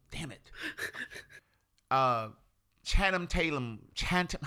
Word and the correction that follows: Channing Tatum: Channing Tatum.